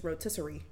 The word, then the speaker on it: rotisserie: It's rotisserie. – Rotisserie? – That's it, right? – Rotisserie. – You keep adding airy. Rotisserie.